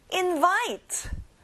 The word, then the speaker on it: invite: 초대하다, invite. One more time, 끝에 츠 발음까지 해주세요.